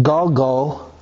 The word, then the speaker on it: galgal: The word wheels is pronounced Galgal, G-A-L-G-A-L, and it's the word wheels, like the wheels of a car.